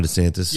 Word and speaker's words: DeSantis: Can you imagine if DeSantis, was that how you say his name? – Ron DeSantis.